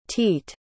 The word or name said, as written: teet